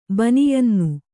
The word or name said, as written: ಬನಿಯನ್ನು